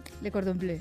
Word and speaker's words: Le Cordon Bleu: Eh, ¿Lo he dicho bien? Le cordón bleu. De cordon bleu. – da igual. – Está bien dicho.